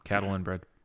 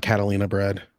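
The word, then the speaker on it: Catalinbread: This is a very tame color palette for Catalan Bread. – I like it. – No, we both said it correctly. – Yeah, it's not Catalina Bread. It's not the fucking Catalina Wine Mixer.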